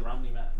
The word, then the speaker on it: Rami Malek: I was gonna say, I was like, is it Rami Malek?